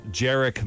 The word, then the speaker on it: Jerick: You saw Chris Circle Jerk McKinnon. – He didn't pronounce the I. – Okay. – He made jerk one syllable. Jerk.